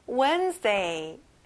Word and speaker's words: Wednesday: 수요일, Wednesday. 친구들, 여기도 앞에 강세가 나오고요. D 발음은 안 나요. Wednesday.